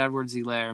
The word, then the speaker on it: Edwards-Helaire: Clyde Edwards-Helaire. Am I saying that wrong? – I think you're saying it right.